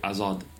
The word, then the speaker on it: Hazard: And he decided to drop both the H and the D. – I didn't. – From the word Hazard.